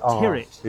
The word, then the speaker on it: Tyrwhitt: It looks like Tyrwhitt. – It looks Tyrwhitt, but it's Charles Tyrwhitt. – It's pronounced Tyrwhitt like spirit.